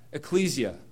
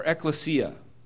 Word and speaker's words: ecclesia: Because in the Greek, this word church is translated from ecclesia or ecclesia. Depends how you say it.